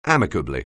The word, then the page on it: amicably: anglais